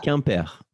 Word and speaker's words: Quimper: Well, apparently it was radar on the freeway near Quimper. How do you pronounce that? – Quimper.